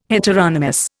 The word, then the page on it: heteronomous: Indian